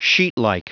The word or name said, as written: sheetlike